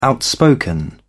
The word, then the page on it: outspoken: British English